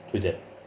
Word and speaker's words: Twitter: Twitter.